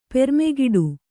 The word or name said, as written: ಪೆರ್ಮೆಗಿಡು